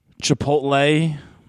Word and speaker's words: Chipotle: They decide we're going to go eat at Chipotle.